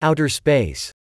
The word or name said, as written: outer space